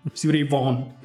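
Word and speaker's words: Stevie Ray Vaughan: Steve Ray Vaughan, per essere corretti nella pronuncia.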